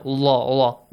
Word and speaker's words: Allah: The word, the letter lam and the word Allah should be pronounced with an a sound. Allah, Allah.